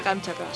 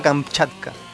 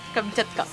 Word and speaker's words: Kamchatka: China ataca a Kamchatka. – China, ese es genial. – No te salió, burro. – ¿Por qué? ¿Cómo se dice? – China ataca a Kamchatka. – Kamchatka.